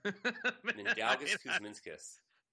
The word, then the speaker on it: Mindaugas Kuzminskas: Mindaugas Kuzminskas.